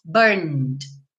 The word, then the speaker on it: burned: Lembrando que o E não é pronunciado. Então, você não vai dizer burned, right? Uhum. You didn't say that, right? Então, você diz burned, burned, ok? Ou você pensa em burnt, B-U-R-N-T.